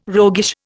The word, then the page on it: roguish: Indian